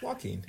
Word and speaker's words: Joaquin: But hearing him talk about the movie he'd seen, like, apparently, I don't actually know how to pronounce his name, so that's why I keep calling him Joaquin Phoenix. – It's Joaquin. – Is that it? – Joaquin.